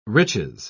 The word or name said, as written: riches